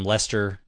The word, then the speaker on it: Leicester: L-E-I-C-E-S-T-E-R. – Oh, well, it's pronounced Leicester.